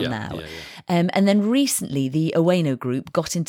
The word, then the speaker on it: Oeno: So yeah, this is a sponsored episode with Oweno, which is spelt O-E-N-O, just to be clear.